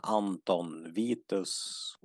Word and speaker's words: Anton Vitus: Och precis som du sa innan, även han Anton Vitus, uttalar man det så? – Ja.